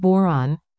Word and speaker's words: Bodhran: Pronounced Boron, rhymes with moron.